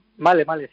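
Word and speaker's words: Malé: La capital es Male, ¿no? ¿Male, cómo se dice? – Sí, Male, sí.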